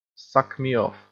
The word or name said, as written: suck me off